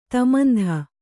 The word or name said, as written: ತಮಂಧ